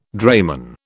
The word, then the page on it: drayman: British English